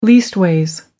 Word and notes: leastways: American English

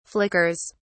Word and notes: flickers: British English

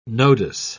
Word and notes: nodus: British English

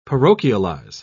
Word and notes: parochialize: British English

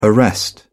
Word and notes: arrest: British English

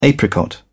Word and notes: apricot: British English

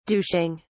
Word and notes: douching: British English

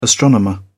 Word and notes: astronomer: British English

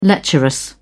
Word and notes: lecherous: British English

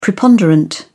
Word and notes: preponderant: British English

